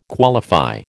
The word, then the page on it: qualify: American English